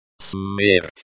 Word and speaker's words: Смерть: Смерт, the C is like the S in sin. The T is like the T in time, but with the tip of your tongue slightly closer to the roof of your mouth.